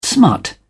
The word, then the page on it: smut: British English